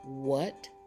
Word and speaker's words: what: The second column has words that start with WH and the H is silent when it's followed by W. Let's say these words together. What, when, where, which, wine, and why.